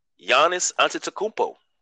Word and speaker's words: Giannis Antetokounmpo: And my man, Giannis Antetokounmpo.